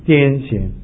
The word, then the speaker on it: 癫痫: 那中文圣经是翻译做癫痫那个字是念痫，不是念简，因为我这个在这个电脑上找简找不到，所以我就去查字典，查字典呢就发现这个病字里面一个休闲的闲，这发音是西安闲，所以不是常常我们讲的是癫痫疯，是不是？是癫痫。